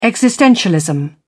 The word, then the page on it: existentialism: British English